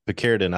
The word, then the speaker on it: Picaridin: Okay, Picaridin.